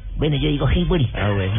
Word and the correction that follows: Highbury: No, señor, se, se pronuncia Highbury.